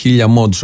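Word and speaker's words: 1000mods: Ναι, Χριστίνα, αγαπητή ακροάτρια, 1000 mods προφέρεται και λέγεται, αλλά είναι ωραίο 1000 mods όπως το γράφουν με αριθμό, 1000 mods, χιλιομόδι.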